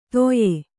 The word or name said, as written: ತೊಯೆ